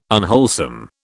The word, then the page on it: unwholesome: British English